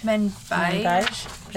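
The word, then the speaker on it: Manbij: The area is now known as Menbai. I think I said that right. – Menbij. – There's a J at the end.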